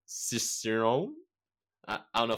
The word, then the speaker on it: Cicerone: Cicerone. – Cicerone. – Think of like sis and sister, sis and sister. Sir, like, hello, sir. Rome. – Okay, so Cicerone.